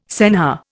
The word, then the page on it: senhor: Indian